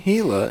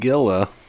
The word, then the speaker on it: Gila: Thank you for saying Gila and not Gila. – Yeah. – Or Gila. Yeah. – Gila.